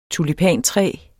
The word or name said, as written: tulipantræ